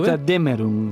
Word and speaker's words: Götterdämmerung: Götterdämmerung.